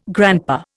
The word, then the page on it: grandpa: Indian